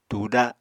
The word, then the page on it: tùda: apatani